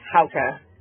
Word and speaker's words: Hauka: It's Hauka.